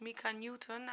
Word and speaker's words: Mika Newton: Ja, met, zeg ik dat goed, Mika Newton uit 2010. – ja, correct ja, me if I'm wrong.